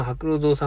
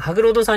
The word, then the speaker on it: 白鷺堂: とりあえず、今の心境あの、日曜日に行った、その白老堂さんの、白老堂だったかな。白鷺と書いて白老って読むんですよね、多分。白鷺堂と書いて白老堂だったと思うんですけど。